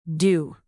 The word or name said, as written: do